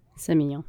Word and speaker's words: Semillon: Semillon.